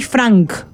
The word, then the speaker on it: Franke: Este es efectivamente un documental sobre un caso muy famoso de Estados Unidos, sobre una mujer que se llama Ruby Frank. Se dice Frank, pero se escribe Franke, como con E, ¿no?